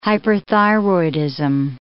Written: hyperthyroidism

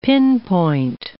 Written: pinpoint

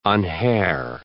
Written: unhair

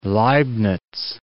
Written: Leibniz

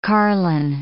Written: carlin

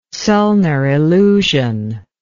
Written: zollner illusion

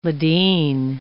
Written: Ladin

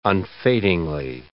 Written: unfadingly

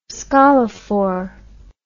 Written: scolophore

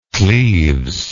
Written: cleves

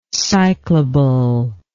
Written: cyclable

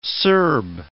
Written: Serb

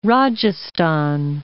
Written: Rajasthan

